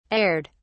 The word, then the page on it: aired: British English